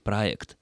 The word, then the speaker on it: проект: Например, Василий Алексеевич пишет: «Один наш ведущий политик относительно недавно стал популяризировать латинское по происхождению слово «проект», произнося его через «э», хотя правильнее было бы произносить «е», так как это слово однокоренное со словами «объект», «субъект» и так далее. И все чиновники стали попугайничать, произносить то же слово «проект» через «э», то есть «проект».